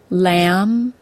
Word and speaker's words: lamb: If a word ends in the letters MB, the B is silent. Let's look at some examples. I already told you the word climb. Some others are thumb, comb, crumb, lamb, limb, bomb, and dumb.